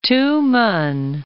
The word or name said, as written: Tumen